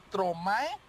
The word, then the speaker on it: Stromae: Mi hit de hoy suena así, suena, bueno, ahí va la pronunciación, es que yo no parlé francés, así que es Stromae.